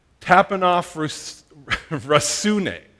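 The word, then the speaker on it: tapeinophrosynē: Now, the New Testament word for humility is kind of a mouthful. It's tapenophrosune.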